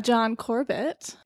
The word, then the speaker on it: John Corbett: John Corbett. He was great. He was really funny. I never know if that's how you say his name. – John Corbett? I think that's right.